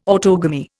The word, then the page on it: autogamy: Indian